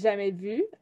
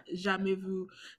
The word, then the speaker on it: jamais vu: mad when people does it make you mad instead of like saying jamais vu, does it make you mad when people say jamais vu? – Oui. Vraiment beaucoup, énormément. Stop pronouncing the S.